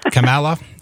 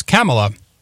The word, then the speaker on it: Kamala: We'll properly pronounce her name today, Kamala Harris, which we learned that last week, not Kamala, or it's Kamala Harris,